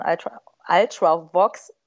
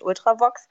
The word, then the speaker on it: Ultravox: Also mit Dancing with Tears in My Eyes war es halt auf jeden Fall, also von Ultravox wird das, glaube ich, ausgesprochen. Ne? Ich habe es halt so deutsch-englisch ausgesprochen mit Ultravox.